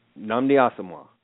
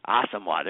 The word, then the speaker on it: Asamoah: Nnamdi Asamoah. – Asamoah, there you go.